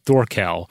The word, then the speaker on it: Thorkel: From my understanding, based on the British History podcast, there were several historical Scandinavian figures from the 11th century that were named Thorkel, including Thorkel the Tall. It's pronounced Thor, as in the Norse god, Kel.